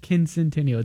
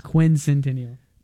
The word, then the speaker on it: quincentennial: What did I say it wrong again? – Quincentennial. It's quincentennial.